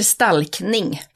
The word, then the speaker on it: stalkning: Ska man säga stalking eller ska man säga stalkning? – Ja, det är en jättebra fråga. Det heter stalkning på svenska, men det engelska ordet är stalking.